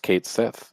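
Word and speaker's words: Cait Sith: I'm very excited to play as Red 13 and Kate Sith, which we now know is pronounced Kate Sith.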